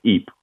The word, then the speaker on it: Ypres: to the town of Ypres, or the city of Ypres, in the western part of Belgium in Flanders, and it's spelled Y-P-R-E-S.